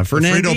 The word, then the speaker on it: Fernandina: In Fernandina. I'm missing a syllable. – Fernandino Beach? – Fernandina. Fernandina.